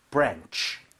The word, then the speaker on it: branch: 자, branch 할 때 ch로 끝나죠? – Yeah. – ch 할 때는 입 모양을 모으고 연습하셔야 돼요. 안 그러면 쓰 하고 발음을 새 버립니다.